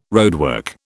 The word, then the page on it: roadwork: British English